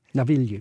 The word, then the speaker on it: Navigli: Navigli.